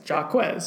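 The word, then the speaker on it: Jaw-Quez: But, you know, here we got was Jaw, J-A-W hyphen Quez, Jarquez.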